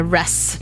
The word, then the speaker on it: Ruess: Pinko Naturues, eller Ress ska man faktiskt uttala det.